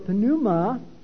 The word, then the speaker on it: pneuma: And then it says her spirit, or pneuma, in English, we the P is silent.